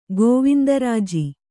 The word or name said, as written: ಗೋವಿಂದರಾಜಿ